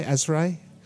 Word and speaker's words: Asrai: My favourite of the English freshwater cryptids has to be the Asrae. I think this is how you spell it. A-S-R-A-I, Asrae? – Yeah, that's how I'd say it, yeah.